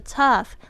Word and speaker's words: tough: Put you in tough situation，这个 tough。 T O U G H，tough 其实就是艰难的，它跟这个 difficult，呃，就是是同义词 tough。注意一下 G H 这边的发音是 f 的声音，tough situations 就是困境况，嗯，tough situation 就是困境。